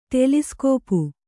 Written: ಟೆಲಿಸ್ಕೋಪು

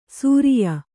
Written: ಸೂರಿಯ

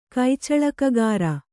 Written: ಕೈಚಳಕಗಾರ

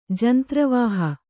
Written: ಜಂತ್ರವಾಹ

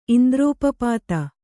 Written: ಇಂದ್ರೋಪಪಾತ

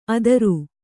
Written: ಅದುರು

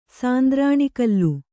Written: ಸಾಂದ್ರಾಣಿಕಲ್ಲು

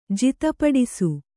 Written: ಜಿತಪಡಿಸು

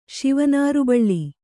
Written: ಶಿವನಾರುಬಳ್ಳಿ

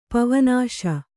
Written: ಪವನಾಶಿ

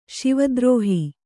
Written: ಶಿವದ್ರೋಹಿ

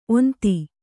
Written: ಓಂತಿ

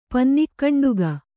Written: ಪನ್ನಿಕ್ಕಂಡುಗ

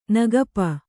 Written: ನಗಪ

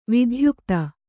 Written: ವಿಧ್ಯುಕ್ತ

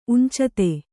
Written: ಉಂಚತೆ

